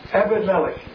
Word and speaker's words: Abimelech: Abimelech. – Abimelech. – Melech. M-E-L-E-C-H.